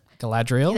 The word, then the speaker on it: Galadriel: Galadriel? – That's it.